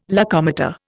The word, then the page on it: locomotor: Indian